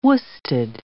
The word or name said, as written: worsted